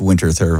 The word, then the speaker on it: Winterthur: I really like Winterthur viburnum, and some people would call it Winterthur and pronounce it differently, but it's Winterthur viburnum for all common English-speaking people here. – And it's hard to pass that TH and just leave it with a T.